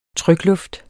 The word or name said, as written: trykluft